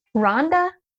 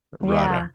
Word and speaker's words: Radha: Rhonda? – Wow. It's a beautiful, it's a beautiful deity. – Radha.